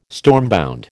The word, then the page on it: stormbound: American English